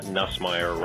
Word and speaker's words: Nussmeier: And I'm just wondering if it's a coincidence that Nussmeier rhymes with bad hire.